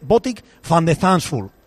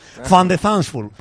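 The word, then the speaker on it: Botic van de Zandschulp: Entonces el propio jugador diciendo cómo se pronuncia, y se dice Botic van de Thansful. – Van de Thansful. – Fan de Thansful.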